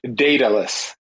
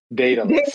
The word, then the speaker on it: Daedalus: It's Daedalus. Daedalus. Like Miami Dade County, Daedalus.